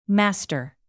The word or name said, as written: master